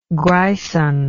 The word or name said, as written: grison